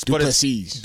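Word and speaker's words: Du Plessis: Strickland versus, is it Duplessis? Is that how you say it? – It's Duplessis. – It's something else. – Duplessis. – Duplessis. That's it.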